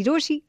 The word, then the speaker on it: Rochie: मेरा नाम है मिर्ची रोशी, नाम की स्पेलिंग है ROCHIE, and let's get talking.